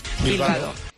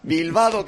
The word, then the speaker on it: Bilbao: Bilbao. Ahí estamos. Bilbao, que rima con bacalao. – Claro. – Y con helado.